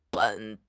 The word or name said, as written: ben3